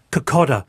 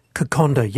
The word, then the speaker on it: kokoda: I hate hearing Fijian raw fish pronounced kokoda. K-O-K-O-D-A. It should be kokonda.